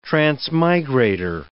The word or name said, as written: transmigrator